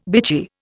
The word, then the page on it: bitchy: Indian